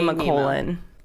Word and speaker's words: Nemacolin: I'll spell it for you. NEMACOLIN. – Finding Nema colon. – What? – Nema colon. – COLIN. – One word. – Colin? – Colon. – Okay.